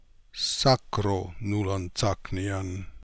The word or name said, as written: sakronoolantsaknian